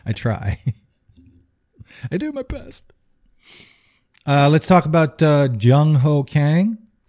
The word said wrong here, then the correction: Jung Ho Gong: No, it's Jung Ho Gong. – Oh, Jung Ho Gong. Not Gong Ho Chang.